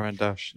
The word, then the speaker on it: Caran d'Ache: I think Caran d'Ache, you pronounce it like that? – Caran d'Ache.